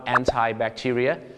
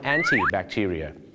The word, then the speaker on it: anti-bacteria: เวลาอเมริกาออกเสียงว่า anti-bacteria แต่ชาวอังกฤษออกเสียงว่า anti-bacteria